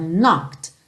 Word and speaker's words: knocked: Honey, when you knocked on my door, não é knocked, lembra que esse E tá pintadinho de cinza, não vai ser pronunciado. Então, knocked, knocked on my door.